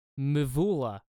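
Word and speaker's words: Mvula: Then it goes Remedy by Laura Muvala. I'm butchering the pronunciation, but she's like this kind of synth poppy. She plays the guitar. Awesome. – What's her name? – Laura Muvula. Muvula. – Muvula. – Yeah.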